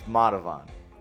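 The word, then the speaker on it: Madhuvan: I was like, how do you pronounce this song? – Is it like Mudhaven? – Madhavan. – Madhavan. – People call it Madhavan, yeah. Madhavan. It's Madhavan.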